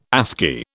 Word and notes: ASCII: British English

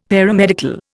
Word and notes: Paramedical: Indian

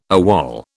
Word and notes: AWOL: British English

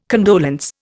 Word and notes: condolence: Indian

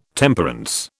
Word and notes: Temperance: British English

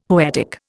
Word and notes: poetic: Indian

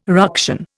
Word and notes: ruction: Indian

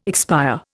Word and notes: expire: Indian

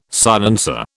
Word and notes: silencer: British English

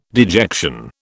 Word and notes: dejection: British English

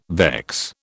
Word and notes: vex: British English

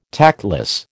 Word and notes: tactless: American English

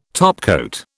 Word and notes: topcoat: British English

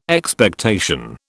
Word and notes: Expectation: British English

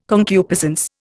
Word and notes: concupiscence: Indian

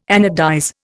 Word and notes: anodize: Indian

